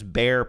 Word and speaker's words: Behr: Is that how you say it? B-E-H-R? I think it's bear. – Bear, yeah. I think it's bear. I think, in fact, their logo is a bear, like an animal, even though it's spelled differently.